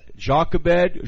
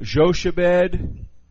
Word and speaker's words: Jochebed: And Jochebed. Joshebed. Jochebed. Okay. There's several different ways to pronounce that.